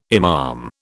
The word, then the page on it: imam: British English